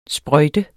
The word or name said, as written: sprøjte